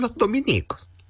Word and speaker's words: Dominicos: Si se alude a los religiosos de la Orden de Santo Domingo, se escribe Dominico, sin tilde en ninguna I. Por lo tanto, es la estación Los Dominicos, la que queda en Las Condes.